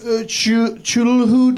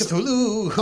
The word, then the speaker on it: Cthulhu: Chulhu dice? – Cthulhu.